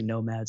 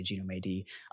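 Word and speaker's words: gnomAD: I never really know how to pronounce it. Some people say NOMAD, some people say Genome AD. I'm going to call it NOMAD because that sounds cool.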